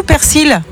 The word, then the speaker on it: persil: Alors normalement c'est persil, ok, ouais. D'après le dictionnaire de l'Académie française, mais le nord et le sud du pays prononcent quand même le L, donc t'as le droit quand même aux deux.